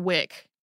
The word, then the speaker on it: weck: My husband grew up in Western New York and told me that everyone where he grew up called it Beef On Wick, not Weck, Wick, like it was spelled with an I.